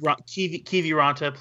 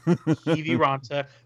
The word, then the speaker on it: Kiviranta: There were goals 10 seconds apart in the third, capped off by Kivaranta hat trick foreseen by Hockey Jerk. – Kiviranta, please get our Lord and Savior's name correctly.